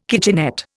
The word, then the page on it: kitchenette: Indian